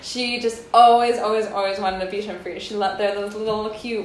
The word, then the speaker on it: Bichon Frise: Is it Bichon Frise? – Frise, okay. Bichon Frise. – Bichon Frise.